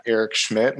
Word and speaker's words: Erich Schmidt: Can find me on Twitter at Erich Schmidt. My name is spelled funny. So E-R-I-C-H-S-C-H-M-I-D-T.